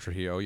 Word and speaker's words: Trujillo: Can you pronounce his name better for me? – It's Robert Trujillo, yeah.